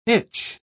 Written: itch

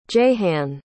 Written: Jehanne

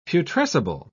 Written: putrescible

